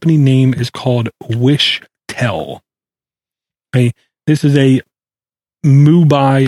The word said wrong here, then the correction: Mumbai: I can't even say it right, Mubai-based company. Mumbai, I think it's how it's pronounced.